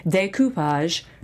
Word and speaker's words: découpage: découpage. Using the French pronunciation, calligraphy, parchment craft, cake decorating, DIY decorating, floral design, cosplay and costume making, basket weaving.